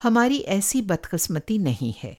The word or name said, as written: बदक़िस्मती